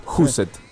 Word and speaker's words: Huset: Husset. – Sí. Husset. ¿De quién? – H-U-S-E-T. – Ah, Husset. – ¿Se puede decir qué carajo es Husset?